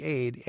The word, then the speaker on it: Aidells: And Bruce Adell, and his last name, by the way, I should spell that for you. It's Aide. It's like Aide, AIDELLS. Bruce Adels.